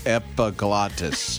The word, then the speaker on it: epiglottis: Oh, and sometimes I get that kernel of corn stuck on my epiglottis.